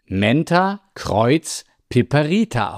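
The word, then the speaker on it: Mentha × piperita: Wissenschaftlich präzise, zum Beispiel bei Vorträgen oder in der Lehre, spricht man ihren Namen Menta Kreuz Piperita aus. Das Kreuzungszeichen wird wie das Wort Kreuz ausgesprochen, um auf die Hybridnatur der Pflanze hinzuweisen.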